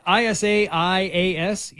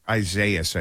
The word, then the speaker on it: Isaias: Isaias? Isaias? – I heard him say it on the weather TV. – Isaias? – I don't remember. That's it. Isaias, I think, is the correct pronunciation.